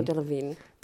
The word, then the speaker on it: Delevingne: Und ich habe nach wie vor gutes, also ich, ich möchte nach wie vor Cara Delevingne auch nicht abstimmen als schlechtes Schauspielerin. Delevingne oder Delevingne? Delevingne, Delevingne. Ich finde, Delevingne hat irgendwas Melodisches, deswegen sage ich immer Delevingne.